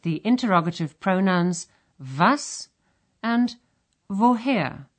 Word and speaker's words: woher: The first sound in these words is a V sound. – Was studieren Sie? – Und woher kommst du? – In German, the written letter W is pronounced like the English V in very.